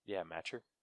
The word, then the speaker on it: Macher: How do I not know how to say his last name? Matcher? M-A-C-H-E-R. – Matcher. – Matcher? Okay.